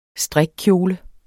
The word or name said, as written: strikkjole